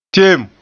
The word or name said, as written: teem